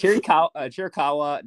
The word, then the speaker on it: Chiricahua: All right, it's Chiricahua National Monument.